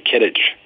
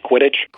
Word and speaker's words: Quidditch: My my bet's on Kittich. Is that how you pronounce it? Quidditch.